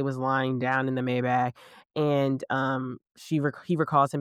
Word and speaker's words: Maybach: Is that how you say it? Maybach? Yeah. She's in a Maybach.